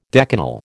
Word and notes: decanal: American English

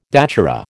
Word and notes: datura: American English

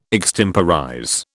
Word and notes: extemporize: British English

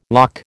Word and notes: loch: American English

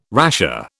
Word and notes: rasher: British English

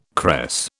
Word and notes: cress: British English

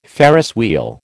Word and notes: Ferris wheel: American English

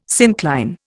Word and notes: syncline: Indian

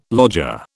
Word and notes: lodger: British English

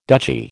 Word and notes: duchy: American English

